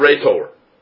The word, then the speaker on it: rhetor: The word orator in the Greek is the word rhetor, R-H-E-T-O-R, rhetor, from which we get our English word rhetoric.